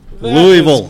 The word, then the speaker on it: Louisville: Am I saying it right? – Louisville. – Louisville. – Louisville. All right. There you go. – Louisville. There's no I. – You really don't Louisville. Pronounce the I.